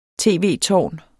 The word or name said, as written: tv-tårn